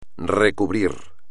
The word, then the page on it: recubrir: espagnol